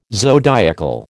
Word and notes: zodiacal: American English

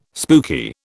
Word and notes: spooky: British English

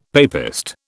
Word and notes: papist: British English

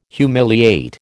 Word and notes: humiliate: American English

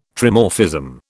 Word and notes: Trimorphism: British English

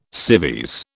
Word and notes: civvies: British English

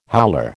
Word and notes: howler: American English

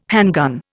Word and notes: handgun: Indian

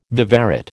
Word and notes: viverrid: American English

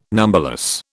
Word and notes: numberless: British English

